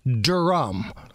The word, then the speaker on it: Durm: The guy's name that is no longer with us, the hero that has left us way too early, was John Durm, D-U-R-M. – Right. – Well, sometimes I've heard people pronounce it as Durham. – Oh. – Right? And maybe this is like an Indiana Southern drawl kind of thing where if you just slow it down, Durm.